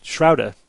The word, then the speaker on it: Schroder: Well, he actually sent a pronunciation guide, and Schrouda is how it's spelled S-C-H-R-O-D-E-R, but he said pronounced S-H-R-O-W-D-A, Schrouda.